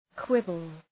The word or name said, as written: quibble